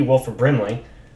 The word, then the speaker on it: Wilford Brimley: Wilford Brimley, who played Noah in Battle for Endor. Okay? So you want to talk about casting... Did I say Wilford Brimley? Did I say that right? – Yeah.